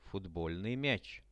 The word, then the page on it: futbolnyj miach: Russian